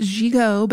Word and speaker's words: gigot: And yes, it is French and pronounced gigot,